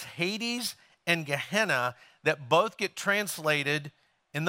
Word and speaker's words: Gehenna: There's another way they described the afterlife, and it was a word that's pronounced Gehenna.